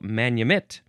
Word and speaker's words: manumit: Manumit is pronounced basically like I'm saying, the emphasis on the last syllable, manumit.